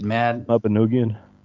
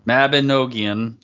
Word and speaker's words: Mabinogion: The Mabinogion, or Mabin, I can't ever say it. – Mabinogion. – Mabinogion, that's how you say it.